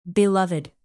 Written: beloved

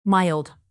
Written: mild

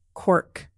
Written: quirk

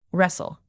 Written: wrestle

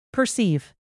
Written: perceive

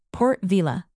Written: port-vila

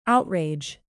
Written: outrage

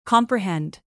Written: comprehend

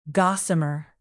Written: gossamer

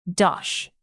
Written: dosh

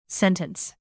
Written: sentence

